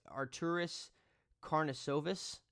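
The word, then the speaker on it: Artūras Karnišovas: It's Arturis Karnisovas.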